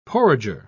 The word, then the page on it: porringer: British English